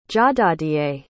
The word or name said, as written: jahdahdieh